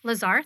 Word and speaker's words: LaZear: LaZear, thank you.